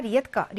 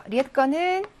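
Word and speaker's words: 리에트거 (редко): 이아 리에트거. 리에트거는 드물게라는 빈도부사고 이 대가 크 발음이 납니다. 리에트거. 아, 트 발음.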